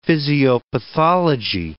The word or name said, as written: physiopathology